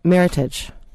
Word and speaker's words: meritage: And that word is, and I hope I'm saying it right, meritage. Rhymes with heritage, right? – Right.